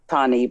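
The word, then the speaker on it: Tannie: Now, we say Tani.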